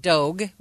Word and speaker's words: Dogue: Dogue, which rhymes with Vogue, open in the city's trendy Mission District.